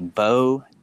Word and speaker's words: Beau: It is pretty simple, Bo Downard, but Bo is spelled fancy, BEAU.